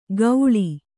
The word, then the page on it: ಗೌಳಿ: kannada